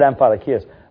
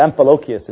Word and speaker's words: Amphilochius: Amphilochius is probably the way. I said Amphilochius, but Amphilochius is the way you'd say his name.